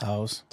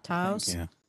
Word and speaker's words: Taos: Taos. – Taos.